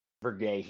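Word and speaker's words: Verhaeghe: You've got Mitchell Stevens, Anthony Cirelli, Eric Chernak, Kevin Shattenkirk, Zach Bogosian, Jan Ruta, Patrick Maroon, Luke Shen, Carter Verhey. Am I saying that right? – Verhey, yeah. – Verhey, okay. So, Verhey, I mean, sorry, Verhey, that's my fault.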